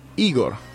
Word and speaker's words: Igor: Esto no es Bastard, esto no es Goblin, esto no es Wolf, esto no es Cherry Bomb, esto no es Boy Esto es Igor. Pronunciado Igor.